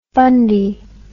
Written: fundie

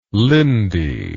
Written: lindi

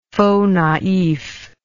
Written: faux naif

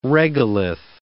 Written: regolith

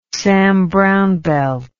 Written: Sam Browne belt

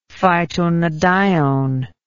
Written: phytonadione

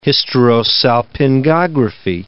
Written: hysterosalpingography

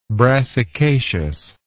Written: brassicaceous

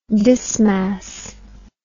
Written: dismas

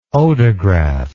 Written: odograph

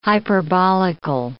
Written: hyperbolical

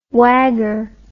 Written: wagger

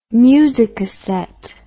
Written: musicassette

